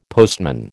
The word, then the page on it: postman: American English